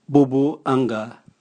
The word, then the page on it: bubuanga: Raga